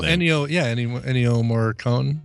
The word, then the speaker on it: Ennio Morricone: Anyway, it's got a mix, and it's all done by Ennio Morricone, or however you say his name. – Oh, Ennio Morricone.